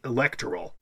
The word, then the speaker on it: electoral: It's the antepenult. It's the first syllable, going backwards. Ul is short, ter is short, so therefore it should be technically electoral. – Okay. – That should be technically the correct pronunciation.